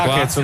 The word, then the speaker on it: Hackett: Si pronuncia Hackett, non Jacquet.